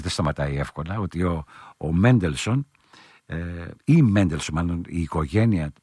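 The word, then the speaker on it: Mendelssohn: τον Εβραίο φιλόσοφο Μόζες, όπως προφέρεται από τους Σάξονες, Μέντελσον.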